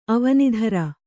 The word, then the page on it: ಅವನಿಧರ: kannada